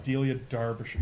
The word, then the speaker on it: Delia Derbyshire: Even though it's an E, the British pronounce that as A. So it's Delia Derbyshire.